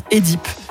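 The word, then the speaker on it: Œdipe: Il ne faudrait pas dire Oedipe, il faudrait dire Édipe. Parce que le O-E, c'est censé se prononcer E et pas E.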